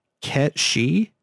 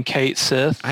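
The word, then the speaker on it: Cait Sith: So apparently that is pronounced Ketshi. – Ketshi? – Yeah. It's the Gaelic word for fairy cat. – Sure. – So we've been saying Kate Sith I think we've been saying Kate Sith completely wrong.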